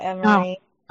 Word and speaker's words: Emery: No, Emery. Oh, okay. – Rhyming with memory.